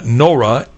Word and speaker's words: Nohra: And by the way, you spell his name G-U-Y, pronounced Guy, Nora, N-O-H-R-A.